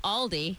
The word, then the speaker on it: Aldi: It's pronounced Aldi.